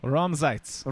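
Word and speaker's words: Raumzeit: Can you say it? – Ramsaitz. – Ramsaitz from German engineered cigars. – German engineered cigars. – How do you spell that? – It's R-A-U-M-Z-E-I-T.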